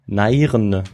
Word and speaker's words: Nairn: Scheiße, wie spricht man das aus? – Nein. – Nairne. – Nairn.